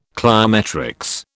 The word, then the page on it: cliometrics: British English